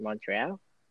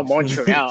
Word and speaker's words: Montreal: If I said the name right, Montreal Impact. – Montreal. – Oh, Montreal. Oh, excuse me. I'm sorry. Yeah, no. – Do you still say it wrong, bro? Montreal.